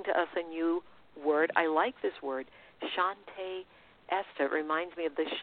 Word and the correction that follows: Shanti Ishta: It's Shante. It's spelled a little differently. It sounds very similar. Ishta, Shanti Ishta.